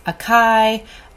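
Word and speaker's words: açaí: It is spelled A-C-A-I. I've heard it pronounced acai, acai. It's acai, and it's also called the Amazonian palm berry.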